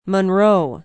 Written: Monroe

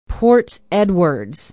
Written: port edwards

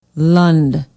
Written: lund

